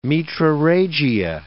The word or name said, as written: metrorrhagia